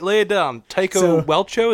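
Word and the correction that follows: Celchu: is that how you say it? – Tycho Selchu. – Selchu, sorry.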